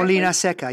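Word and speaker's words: Molinaseca: I believe Molenseca, is that pronounced correctly? – Molina Seca, yeah.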